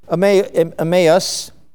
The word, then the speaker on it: Emmaus: Now you look that up and it's pronounced Emmaus.